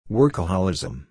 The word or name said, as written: workaholism